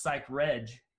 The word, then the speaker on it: Psychreg: And it's pronounced Psych Reg, not Psych Reg.